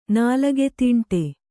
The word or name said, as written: ನಾಲಗೆದೀಂಟೆ